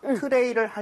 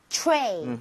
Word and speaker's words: tray: TR. 그다음에 모음이 나오게 되면 치읓 비슷하게 발음이 될 수밖에 없습니다. 왜? R 발음 때문에. 그래서 입모양을 모은 상태로 트레이를 하려고 노력을 해볼게요. 이런 느낌이에요. 트레이. 트레이. – Tray, tray.